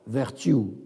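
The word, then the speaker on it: Vertue: George vertu, ça s'écrit comme vertu avec un E, V-E-R-T-U-E, e.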